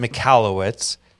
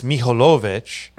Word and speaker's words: Michalowicz: Well, I butchered it. I pronounce it. You're probably right. I pronounce it Michalowicz. In very famous. – In the motherland, it's Michalowicz. So you can say whatever you want.